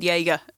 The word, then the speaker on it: Jaeger: And we thought this dog was called Diego for a long time, but it turns out he was actually called Jaeger. But, you know, just, it was... – With a hard J. – Yeah. – Yeah. – It was Jaeger, like Jägermeister.